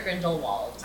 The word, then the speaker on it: Grindelwald: Is it pronounced Grindelwald or Grindelwald? – I mean, Grindelwald is the anglicized version, but Grindelwald is how he identifies.